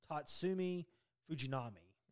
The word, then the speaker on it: Tatsumi Fujinami: I hope I'm not butchering this name. Tatsumi Fujinami. – Mm-hmm. That sounds right. – It sounds familiar to me. That's how it's been said.